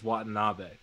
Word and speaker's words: Watanabe: You can play the segment while I say, as well, Bembry had 13 points, and Yuta, Watanabe? I think we're on the pronunciation is Watanabe.